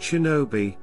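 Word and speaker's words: shinobi (忍び): In the native Kanyomi reading, it is pronounced shinobi, a shortened form of shinobi no mono.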